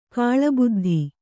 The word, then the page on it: ಕಾಲಬದ್ಧ: kālabaddha